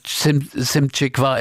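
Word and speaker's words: Szymczyk: Joe Walsh sagt Simcik.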